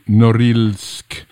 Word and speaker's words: Norilsk: Now, you grew up in the industrial city of, and forgive me if I pronounce this incorrectly, Norilsk. – Totally right.